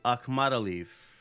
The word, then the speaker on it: Akhmadaliev: Oh, speaking of fully out, Morojan Akhmad, let me pronounce this correctly, Akhmadaliev. There we go. That's how it's actually done.